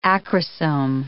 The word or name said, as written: acrosome